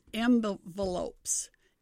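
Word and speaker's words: Mvelopes: And then there's, it's pronounced kind of strange, but it's Envelopes. M-V. Okay.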